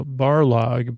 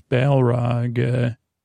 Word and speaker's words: Balrog: Is that how it's spelled? B-A-L, Barlog, but it's Balrog.